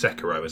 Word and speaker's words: Sekiro: Oh, wait, it's Sekiro, isn't it? That's how you meant to say it. – I think so, yeah. That's how they say it in the game.